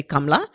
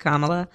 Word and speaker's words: Kamala: If you were asking my grandmother, she'd say Kamala. I usually help people pronounce it by saying, well, just think of the hyphen, a comma, and then add a la at the end, and that's it, Kamala.